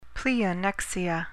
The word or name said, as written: pleonexia